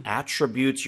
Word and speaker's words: attribute: 자, 여기서는 동사이기 때문에요. Attribute, 이렇게 발음을 하게 되는데 만약에 명사이면서 이 똑같은 스펠링 attribute를 쓰면 attribute라고 해서 어떤 것의 특징을 얘기합니다.